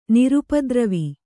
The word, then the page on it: ನಿರುಪದ್ರವ: nirupadrava